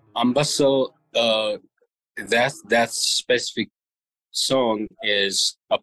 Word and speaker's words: Ambassel: Ambasel? – Yes, that's right.